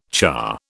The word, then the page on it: cha: British English